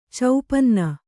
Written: ಚೌಪನ್ನ

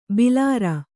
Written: ಭಲರೆ

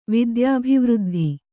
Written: ವಿದ್ಯಾಭಿವೃದ್ಧಿ

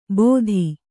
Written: ಬೋಧಿ

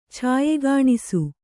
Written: ಛಾಯೆಗಾಣಿಸು

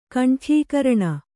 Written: ಕಂಠ್ಯೀಕರಣ

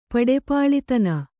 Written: ಪಡೆಪಾಳಿತನ

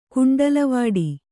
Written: ಕುಂಡಲವಾಡಿ

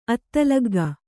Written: ಅತ್ತಲಗ್ಗ

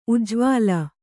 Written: ಉಜ್ವಲ

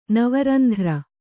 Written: ನವರಂಧ್ರ

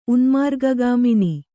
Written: ಉನ್ಮಾರ್ಗಗಾಮಿನಿ

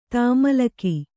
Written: ತಾಮಲಕಿ

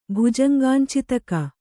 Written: ಭುಜಂಗಾಂಚಿತಕ

